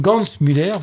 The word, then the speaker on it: Gans-Müller: Et donc ce que dit effectivement cette Catherine Hans-Müller qui a écrit ce livre, pas Catherine Hans-Müller, pardon, excusez-moi, j'écorche son nom, Catherine Gans-Müller, voilà,